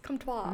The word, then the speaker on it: Comtois: Yes, but Comtois is... – Comtois.